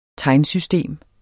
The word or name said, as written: tegnsystem